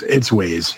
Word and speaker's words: Waze: I liked Waze or Waz or whatever. I don't even know how it's supposed to be pronounced. – Yeah, it's Waze. Yeah.